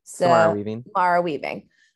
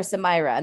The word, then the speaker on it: Samara Weaving: Samara Weaving. – I always want to call her Samira, and that's Samara Wiley. Samara Weaving. I can't do it.